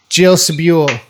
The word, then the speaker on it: Jill Sobule: Jill Sobule.